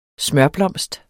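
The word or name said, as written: smørblomst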